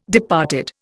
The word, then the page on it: departed: Indian